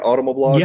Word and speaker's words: Automoblog: Am I saying that right? Automoblog? – Yes, that's correct.